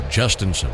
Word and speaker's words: Jhostynxon: J-H-O-S-T-Y-N-X-O-N. Pronounced Justinson. And I was looking at Baseball Reference. They had the pronunciation as Justinson, but we were chatting with some of the media relations members for the Pirates and said, no, Justinson. That's how he says it.